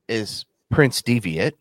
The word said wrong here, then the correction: Devitt: Devitt. Yeah, Prince Devitt. – I know. – Finn Balor. – The other one was Ryushi, R-Y-U-S-U-K-E.